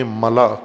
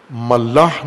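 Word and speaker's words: ملأ: ملا کہا جاتا ہے، ملا، ملاح نہیں ہے، بعض غلط تلفظ کرتے ہیں، ملا، میم لام الف حمزہ، ملا، جیسے خلا ہوتا ہے،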